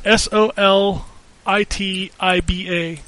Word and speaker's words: Solitiba: S O L I T I B A.